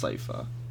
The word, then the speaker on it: Sypha: Cypher. – Sypha. Is it Cypher or Sypha? Sypha. woman who does magic. Magic girl. – I think it's, like, pronounced Sypha.